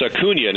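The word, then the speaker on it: Acuña: And it's pronounced Acuna now, I just learned recently. There's an Enye in there, Ronald Acuna.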